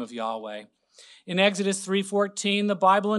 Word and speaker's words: Yahweh: These three features are, number one, the name of the God, Yod-Heh-Vav-Heh, often pronounced Yahweh.